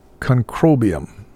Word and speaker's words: Concrobium: Concrobium. Huh? – Concrobium. – Concrobium. The middle syllable.